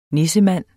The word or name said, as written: nissemand